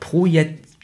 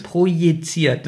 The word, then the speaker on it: projiziert: Ich kann das nicht aussprechen, das Wort projiziert. – Projiziert. – Ja, so wie Projektor, aber projiziert.